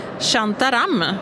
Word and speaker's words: Shantaram: På andra plats ligger När ingen lyssnar och på tredje plats ligger Shantaram, om jag uttalar det rätt. – Det gör du.